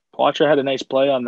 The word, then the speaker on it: Poitras: Although we found out, by the way, it is pronounced differently according to the Guelph Storm. Poitras.